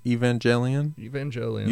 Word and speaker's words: Evangelion: Evangelion. – You say Evangelion. – I just like evangel or evangelist. – Yeah, yeah. – It's just a variation on that word, so that's how I've always pronounced it.